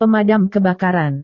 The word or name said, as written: pemadam kebakaran